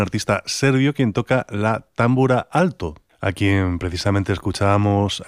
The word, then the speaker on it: támbura: Y, por ejemplo, la primera támbura, que más bien así lo pronunciarían los húngaros, es la de Aaron Ereditch, que en parte de esta pieza también se ocupa del tambor tapán.